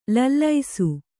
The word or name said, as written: ಲಾಲಿಸು